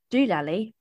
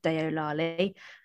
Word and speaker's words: Deolali: Doolally being the Englishman's pronunciation of the town, Deolali, again pronounced wrong, but they pronounced it as doolally because it's spelled D-E-O-L-A-L-I.